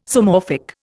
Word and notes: Zoomorphic: Indian

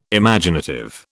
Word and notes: Imaginative: British English

